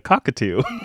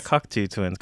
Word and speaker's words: Cocteau: Cocteau? – Yeah. Cocteau Twins. Okay. So I said that, but I used to pronounce this band as a cockatoo. – That's the Cocteau Twins. – Yeah, Cocteau Twins.